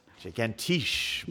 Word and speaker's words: Gigantisch: To celebrate National Bratwurst Day on Wednesday, August 16th, Hofbrauhaus will be serving a special called, and I'm going to guess this is how it's pronounced because it's written out this way, Gigantisch Bratwurst. – Gigantisch. Gigantisch. Yeah. You can say it with a German accent, right? – That was the best.